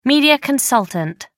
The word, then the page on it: media consultant: American English